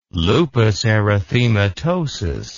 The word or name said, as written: lupus erythematosus